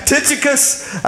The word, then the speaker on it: Tychicus: I don't think you said it right. Say it again. Tychicus.